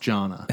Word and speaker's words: Johanna: J-O-H-A-N-N-A. – Joanne? – No, it was Jonna, but it had an H in it. But it was pronounced Jonna.